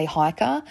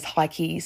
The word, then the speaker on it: Heike: Now, before anyone says anything, in case you're German or speak German, I've looked up how to say this. 9 out of 10 people say Hiker, and that one person out of 10 says Heike. So I'm going to say Hiker. It's H-E-I-K-E.